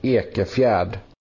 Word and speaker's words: Ekefjärd: Elliot Ekefjärd.